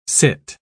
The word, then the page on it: cit: British English